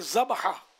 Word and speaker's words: ذبح: ذبح ویسے ذبح صحیح تلفظ ہے، جی ہاں میں نے کیا ہے۔